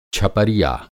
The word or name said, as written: छपरिया